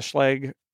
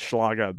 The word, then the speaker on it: Schlage: I've got the Schlage lock, and that's how they pronounce it, even though as a German student, I really want to call it Schlage, but it's not a Schlage because it's Americans.